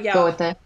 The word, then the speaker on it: Goethe: And we're also talking about, historically, Johann von, you're way better at pronouncing this than me, but Goethe.